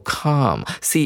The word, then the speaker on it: calm: When listening to calming music，先来看一下这个形容词是有 calm，c a l m，这个 l 没有发音，它是一个动词。